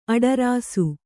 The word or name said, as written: ಅಡರಾಸು